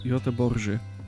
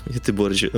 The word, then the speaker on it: Göteborg: E, e jak się wymawia Göteborg po, po szwedzku? Jeszcze raz im powiedz. Göteborży. Göteborży.